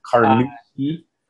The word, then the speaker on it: Carnoustie: Carnoustie.